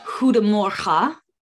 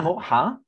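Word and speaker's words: God morgon: Huda Morha is good morning. Huda Morha. – Huda Morha? – Huda Morha. – But it's spelled God Morgan. – Yes. Yeah, good Morgan. Yeah. Huda Morha. – Huda Morha. – Morha. – Morha. – That's it. That's better.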